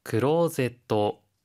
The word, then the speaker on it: クローゼット: クローゼット。 – クローゼット 옷장 붙박이장인데요. 혹시 발음 팁 있을까요? – 체어는 의자를 뜻하는 영어 체어의 일본어 발음입니다. 그리고 클로젯은 중간에 장음, 소음에도 신경 써주세요.